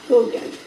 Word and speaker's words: Haugen: Her name is Lindsay Haugen, and that's H-A-U. What? That's lucky. H-A-U-G-E-N. – Okay. – I actually think it's pronounced Hogan. – Like Hogan's Heroes? – Like Hogan's Heroes, right.